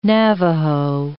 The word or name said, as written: Navajo